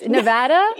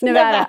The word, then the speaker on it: Nevada: Nevada? – Yes. – Nevada. I said it right.